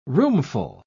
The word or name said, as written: roomful